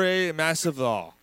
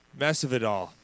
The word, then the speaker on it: Masvidal: Masvidal. It's 35-15. Am I saying his last name right? – Yeah, Jorge Masvidal. I believe that's the proper pronunciation. I could be wrong.